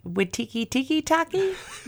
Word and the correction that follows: Taika Waititi: I can never say his name. – I don't even want to say it. I just love that. – Watiki Taki, that's his new name. No, really, what is it? – Taika Waititi.